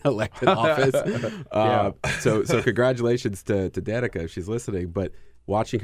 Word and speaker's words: Danica: Danica, is that how you pronounce it? – Danica.